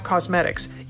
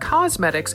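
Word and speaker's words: Causemetics: It's because it's not cosmetics, it's cosmetics. Cause is in the name for a reason.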